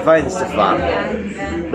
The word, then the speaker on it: Weihenstephaner: Brewed by the Bayerische Staatsbrauerei Weihenstephaner. the Bavarian State Brewery, Weinsterfahrer, competently pronounced.